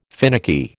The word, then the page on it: finicky: American English